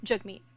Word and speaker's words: Jagmeet: Is Jagmeet Singh, that, did I say it right? – Jagmeet. Yeah.